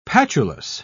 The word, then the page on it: patulous: British English